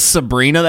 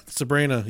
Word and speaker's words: Sebhrenah: Whoa, that's Sabrina? That's how that's spelled? S-E-B-H-R-E-N-A-H. – Sabrina, yeah.